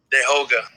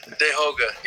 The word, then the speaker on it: Tehoka: So, my name is spelled with a T. So, you think it's Ta or whatever. But really, you would, like, it's Dayhoga, Dehoga. – Like, have a nice day, Hoga.